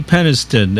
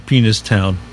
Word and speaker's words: Peniston: I'm told it's pronounced C.C. Peniston and not Penis Town, because that's a whole other place.